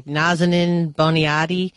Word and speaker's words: Nazanin Boniadi: She's Iranian-born, London-raised, and I'm not sure how to say her name. It's like Nazanin Boniadi. – That sounds good to me.